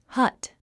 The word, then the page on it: hut: hʌ́t; ハト